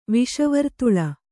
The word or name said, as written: ವಿಷವರ್ತುಳ